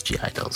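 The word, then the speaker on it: (G)I-DLE: I know it's pronounced Idol, but you know, I think everybody knows it as G Idle, so I'm probably just gonna say it like that.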